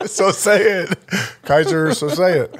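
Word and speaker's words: Keyser Söze: It's Kaiser say so. It is Kaiser say so. – No, it's Kaiser Sose.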